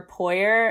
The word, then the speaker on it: Poirier: well my website is under tatum rain and then everything else would be under tatum r poirier and my last name is spelled p-o-i-r-i-e-r so okay thank you